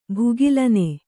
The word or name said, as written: ಭುಗಿಲೆನ್